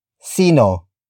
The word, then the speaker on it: sino: Word for word, it's sino, ang, tatay, mo.